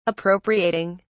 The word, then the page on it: appropriating: British English